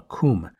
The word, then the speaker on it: cwm: Now, at the end of the glacier is a great, steep-walled, semicircular basin called a khum. This specific khum was dubbed the Western Khum. A side note about this, khum is spelled C-W-M, which is pretty odd. It is Welsh and it means valley.